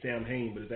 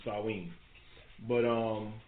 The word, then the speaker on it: Samhain: This is, it's not it's spelled S A M H A I N. Some people say Samhain, but it's actually Samhain. But um, Samhain is one.